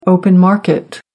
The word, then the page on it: open market: American English